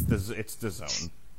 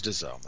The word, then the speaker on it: DAZN: Yeah, but it's DAZN. – Okay, it is DAZN, that's true. – You know what DAZN rhymes with, right? – No. – Calzone!